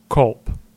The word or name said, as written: Colp